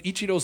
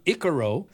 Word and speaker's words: Ichiro: Long ago, a SportsCenter anchor called Ichiro Suzuki Ikaro.